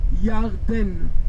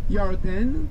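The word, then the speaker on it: Yarden (ירדן): How do you pronounce Jordan in Hebrew? – Yarden. – Yarden, which is also a name of a good wine.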